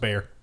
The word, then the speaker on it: Bexar: B-E-X-A-R. – B-E-X-A-R. – It's pronounced Bear. – That, really? – Yeah, it's Bear County.